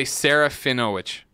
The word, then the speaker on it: Serafinowicz: And I'm totally saying his name wrong. – Pete. Yeah, well, it's better than I would have done. – That's right. – I would say Serafinowicz.